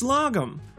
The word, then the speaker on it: lagom: It's logum. That's spelled L-A-G-O-M.